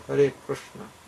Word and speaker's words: Hare Krishna: It's actually Hare Krishna. Krishna Krishna. That's, as far as I know how to pronounce Sanskrit, that's the best as I can get. That's kind of close to it.